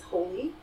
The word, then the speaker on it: Holi: Another one of our big holidays is Holi. It's spelled H-O-L-I. – That sounds like it sounds.